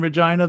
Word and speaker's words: Regina: That's in Regina, there, Brad, and Russell is a good time. – Man, that was one of the funniest things I've ever seen. Is like Regina rhymes with fun.